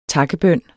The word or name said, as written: takkebøn